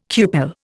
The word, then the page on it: cupel: Indian